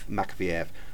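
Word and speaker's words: Makavejev: SDR also showed two films by Dusan Makiyeves. How do we pronounce this? – I think it's Makfiev. – Makfiev.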